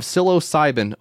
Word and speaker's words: psilocybin: Well, Schedule 35 is removing the stigma around drugs, particularly magic mushrooms, with precisely measured out micro doses of psilocybin.